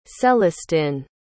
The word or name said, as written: célestin